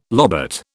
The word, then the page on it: lobate: British English